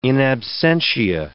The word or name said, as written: in absentia